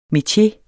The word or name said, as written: metier